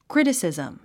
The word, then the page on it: criticism: krítəsìzm; クリティシズム